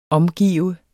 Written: omgive

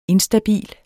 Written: instabil

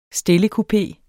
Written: stillekupe

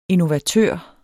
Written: innovatør

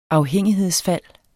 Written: afhængighedsfald

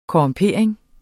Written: korrumpering

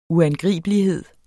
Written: uangribelighed

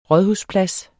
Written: rådhusplads

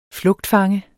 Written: flugtfange

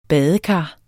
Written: badekar

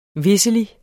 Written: visselig

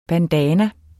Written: bandana

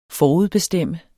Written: forudbestemme

